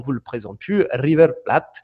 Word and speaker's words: River Plate: C'est un truc que je n'ai jamais su comment les Argentins vraiment le prononcer, si c'était Plate ou Plate. – Je crois que j'ai toujours entendu plate. – Plate. Il me semble bien. Ouais, ouais, c'est étonnant, il commence à l'anglaise presque.